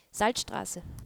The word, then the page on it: Straße: female